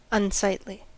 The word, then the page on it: unsightly: American English